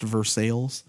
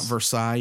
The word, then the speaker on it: Versailles: Some of the ones that I've gotten to do recently, I would say at St. Andrew's Anglican up in Versailles, Kentucky, which is pronounced Versailles, not Versailles, even though it's spelled like Versailles.